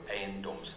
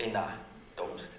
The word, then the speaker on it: Eiendoms: It's spelled E-I-E-N. – Eiendoms. – It's at E-I-E-N-D-O-M. Eiendoms. – Can we get in one more sort? – As it is there, it says it's very Aina. So the word is spelled incorrectly. It's E I E. – Or N. Eiendoms. – Eiendoms, not Aina Doms.